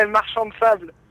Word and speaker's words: Marchand de Sable: For example, in that song that I'm going to pronounce this wrong, but Marchand des Sables, or... – Yeah, Marchand de Sable.